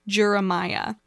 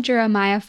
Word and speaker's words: Juramaia: We're going to actually be talking about one of the first of our kind, the first placental mammal, Juramaia, which is how I think it's pronounced. Um, Jeremiah, like Jeremiah was a bullfrog, yeah.